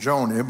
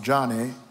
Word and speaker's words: Joni: Born the youngest of four daughters in 1949, Johnny Erickson Tada, I used to call her name Joni, but her name is pronounced Johnny after her father, Johnny Erickson Tada lived a very active life in Baltimore, Maryland.